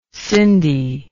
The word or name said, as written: cindy